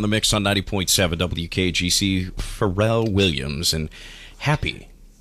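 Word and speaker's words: Pharrell: I say it wrong every time. I call him Farrell. It's very hickish of me. Farrell Williams, and he's got a little song there from the movie Despicable Me 2, huh? – Mm-hmm.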